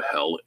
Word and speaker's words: Hel: Let's talk a little bit about Hel, H-E-L, the underworld. Heimdall desires to return to Idun from the underworld by Emil Doppler in 1881. Hel, Old Norse hidden, pronounced like the English word hell, H-E-L-L, is the most general name for the underworld where many of the dead dwell.